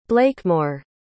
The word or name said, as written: blakemore